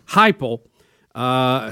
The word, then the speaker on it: Heupel: They brought in Josh, how do you say his last name? – Heupel. – Heupel, who was the University of Central Florida's football coach.